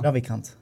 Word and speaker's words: Ravikant: Ja. Ravikant. Ravikant, ja det känns, ah, inte radikant, radikal.